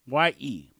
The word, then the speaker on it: Ye: Y-E, pronounced Ye, Y-A-Y.